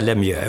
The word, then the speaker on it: Lemieux: Så säger de, en del säger Lemieux, en del säger Lemieux, vad är rätta uttalet? Han tittade på mig som en jävla idiot, som om, vad spelar det för roll? Mm. Men jag vill veta, hur säger din mamma och pappa? Lemieux? Och därför är jag ju rätt att säga Lemieux, och inte som amerikanerna säger.